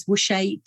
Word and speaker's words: Wusche: So it's V-I-C-K-I, make sure it's an I, and my surname is Wusche, W-U-S-C-H-E.